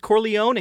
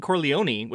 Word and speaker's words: Corleone: And then the other one is Corleone and Corleone, which I noticed they kind of say both in the movie.